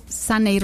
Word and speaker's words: Saney: Yo estoy en redes sociales como DRA, la sigla de doctora, DRA, sí, Saney Rodríguez, mi nombre es raro, se escribe S-A-N-E-Y Rodríguez, mis papás, culpables.